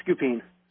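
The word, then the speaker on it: Scupien: Scoopin.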